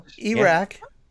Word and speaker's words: Erack: Eric Shea, also known as Dynasty Iraq, E-R-A-C-K. Probably uh-huh. pronounced that wrong, but whatever. – No, know, that's right. Iraq.